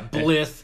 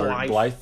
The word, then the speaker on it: Blythe: I appreciate that you pronounced Randy Bly's last name correctly. – Yeah, Blith. – Or Blythe. Blythe.